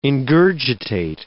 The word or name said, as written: ingurgitate